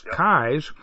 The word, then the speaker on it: Keyes: So for folks who are not familiar with the area, Kai's Peak, it's spelled K-E-Y-E-S, but it's pronounced Kai's. So if you're looking for it, you know, look for something. It starts K-E-Y, and you'll find it.